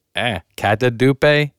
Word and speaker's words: catadupe: Kadupe. Katadupe. C-A-T-A-D-U-P-E.